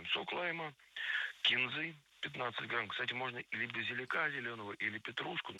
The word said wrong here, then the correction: базилика: Базилика – это неверно. Правильный вариант – базилика. Ударение ставим на третий слог, и там его сохраняем во всех формах.